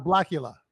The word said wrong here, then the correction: Bakula: Oh, no, sorry. Scott Bakula. I think Bakula. Yeah.